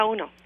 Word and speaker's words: Ono: Yeah, Ono.